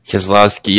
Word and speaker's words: Keselowski: Keselowski, yes.